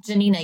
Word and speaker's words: Janina: Janina, yeah.